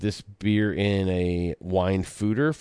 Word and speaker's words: foeder: It's tart, complex, and it's aged in old wine fodder. In an old wine fodder, F-O-E-D-E-R. What the hell is a fodder? So it's actually pronounced fooder, and a fooder is basically an oversized barrel.